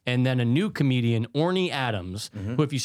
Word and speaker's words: Orny: Oh, so Orny is ornery.